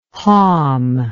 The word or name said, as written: halm